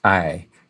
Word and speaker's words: IJ: The Dutch language has a vowel that English doesn't have, which is written as IJ, both capital, and it's pronounced IJ. And that also happens to be the name of this body of water.